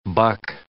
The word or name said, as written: buck